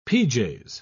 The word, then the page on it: pj's: British English